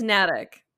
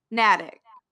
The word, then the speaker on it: Natick: I think it's Natick. – Natick.